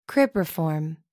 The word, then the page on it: cribriform: American English